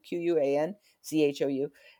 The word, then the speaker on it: Quanzhou: One of the cities that I'm very interested in is a Chinese port called Quanzhou, and Quanzhou is spelled with a Q, Quanzhou.